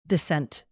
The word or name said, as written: dissent